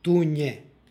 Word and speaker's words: tunje: Ovo je prilog u kome imamo nj u drugom slogu, dakle tunje.